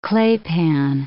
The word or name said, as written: claypan